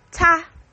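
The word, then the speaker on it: 他: And he is pronounced as ta.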